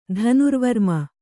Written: ಧನುರ್ವರ್ಮ

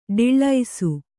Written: ಡಿಳ್ಳೈಸು

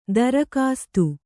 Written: ದರ್ಕಾಸ್ತ್

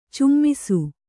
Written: ಚುಮ್ಮಿಸು

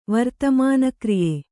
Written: ವರ್ತಮಾನಕ್ರಿಯೆ

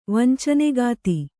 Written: ವಂಚನೆಗಾತಿ

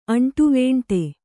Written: ಅಂಟುವೇಂಟೆ

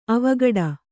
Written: ಅವಘಡ

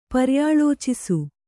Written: ಪರ್ಯಾಲೋಚಿಸು

